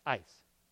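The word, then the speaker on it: ice (εἰς): The word translated result is E-I-S, which is pronounced ice. Ice.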